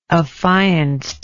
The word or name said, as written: affianced